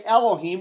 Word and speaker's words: Elohim: When we say Elohim, we're pronouncing it in Hebrew. El is the word high or strong, and then im on the end means plural. So it's like saying Els.